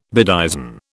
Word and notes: bedizen: British English

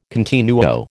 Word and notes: continuo: American English

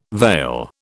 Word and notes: vail: British English

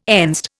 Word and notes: Enzed: Indian

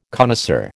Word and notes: connoisseur: American English

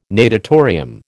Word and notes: natatorium: American English

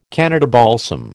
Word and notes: Canada balsam: American English

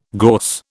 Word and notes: gorse: British English

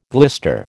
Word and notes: glister: American English

